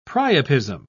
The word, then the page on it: priapism: British English